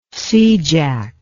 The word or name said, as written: seajack